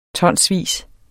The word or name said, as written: tonsvis